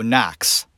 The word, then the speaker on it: Knox: In America, that last name, the K, it's like it's not there. It really should be N-O-C-K-S. When you look at it, you go Knox. That's how it's pronounced. Not Knox.